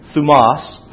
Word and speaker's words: thumos: The emphasis is on the second syllable, thumos.